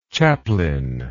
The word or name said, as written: chaplainship